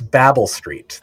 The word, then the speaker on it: Babel Street: I think it's Babble Street. That's generally how people that I've talked to who are familiar with the company pronounce it. – Sure. – I think that's how most Americans pronounce, like, the Tower of Babel in the Bible. – Yes. – And I think that's what they're going for.